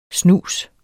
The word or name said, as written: Snusen